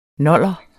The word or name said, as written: noller